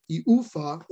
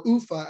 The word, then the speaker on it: Ufa: They also announced that they have loaned him back to his KHL club, which is pronounced Salavat Ulaev Ufa, or Ufa, as most people just say, for the remainder of the 2021-22 KHL season.